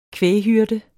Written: kvæghyrde

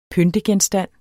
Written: pyntegenstand

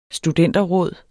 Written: studenterråd

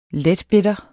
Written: letbitter

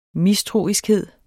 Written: mistroiskhed

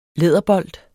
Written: læderbold

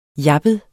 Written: jappet

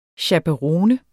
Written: chaperone

